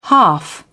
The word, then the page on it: half: British English